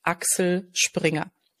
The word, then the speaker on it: Axel Springer: Okay, so the German pronunciation, Axel Springer.